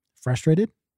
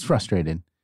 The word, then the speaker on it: frustrated: Frustrated? – Yeah. – No, it's frustrated. Or frustrate. But people pronounce it without the first R. And they say frustrate or frustrated.